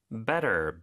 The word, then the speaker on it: better: Better, B-E-T-T-E-R. – 네, better. T가 두 개 들어가지만 물론 하나만 발음하고 모음 사이에 껴서 리을처럼 부드럽게 발음이 되죠.